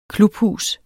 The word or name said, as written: klubhus